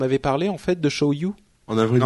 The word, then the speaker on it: Show You: Alors vous, vous avez peut-être vu en fait quand j'en avais parlé la première fois, euh, c'est l'application Show You, comme ça se prononce, S-H-O-W-Y-O-U,